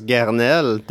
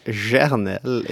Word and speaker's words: Gernelle: Yeah, we thought it was uh, cyclocross Garnel. Turns out it's cyclocross Gernelle. It's, it's a soft G.